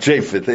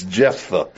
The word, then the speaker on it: Jephthah: My whole life, he was Japheth. And it's not Japheth, it's Jephthah.